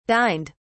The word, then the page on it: dined: British English